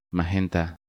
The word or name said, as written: Magenta